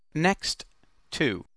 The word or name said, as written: next to